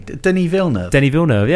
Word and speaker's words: Denis Villeneuve: That's a pretty upfront, you know, like a directed by my good friend Denis Villeneuve as well, whose name I've probably just butchered again for an eighth time on this podcast. – No, I think you said that about right, didn't you? Denis Villeneuve, yeah. Perfect. Denny, if you are listening this time, I've finally got the hang of it.